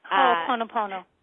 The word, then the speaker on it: Ho'oponopono: Or you can use something that's called Hopa Open Open Opa. I don't think that's actually how you say it, but Ho'oponopono. – Yes, there you go. And she knows how to say it.